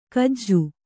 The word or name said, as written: ಖಜ